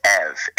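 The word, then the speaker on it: ev (εὐ): where I got the Greek prefix EU, which is pronounced ev in Greek, and put that on topia, which means place.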